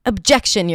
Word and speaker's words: objection: So the reason why I want to talk about the word objection, because it has a fun little schwa at the beginning, even though it's spelled with an O.